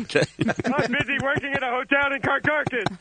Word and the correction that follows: Caracas: I think it's Caracas. – Oh. I've been saying it wrong all this time.